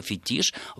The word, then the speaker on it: фетиш: И слово фетиш в английский язык, так же, как и в русский, заимствованы из французского. И ударение должно стоять на последний слог, и никаких оснований для перемещения его на первый нет и быть не может.